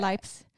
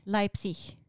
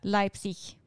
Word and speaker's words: Leipzig: Leips? Leipzig. Leipzig. Ja, du, du är snart där. Du klagar så mycket på mitt uttal. – Jag försöker. Jag sa ju att du snart är där. Leipzig.